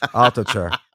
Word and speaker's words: Altucher: Hey, but the real way to pronounce it is Altucher fast. Altucher.